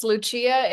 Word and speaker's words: Lucia: It's Lucia. It's named after my grandmother, my Italian grandmother, but I know it's pronounced Lucia elsewhere, but I say Lucia.